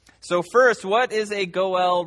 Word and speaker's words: Goel: And the two institutions that we need to know something about are, first, the institution of the Goel Redeemer. The Goel Redeemer. Goel is spelled G-O-E-L, if you're taking notes.